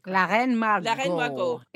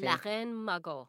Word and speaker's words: La Reine Margot: I'm going to look into the Lorraine Margot one. Lorraine Margot. How do I say it? – La Reine Margot. It's really good movie. – La Reine Margot.